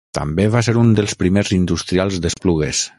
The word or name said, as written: industrials